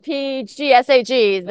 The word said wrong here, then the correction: PSGAG: The PSG. The polysulfated glycosaminoglycans. – Perfect. Thank you. – PSGAGs. Yes. – For the rest of us, yeah. The other one. – Yeah. Just PSGAG.